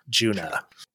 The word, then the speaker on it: Djunah: DJUNAH, Juna. – It's a silent D.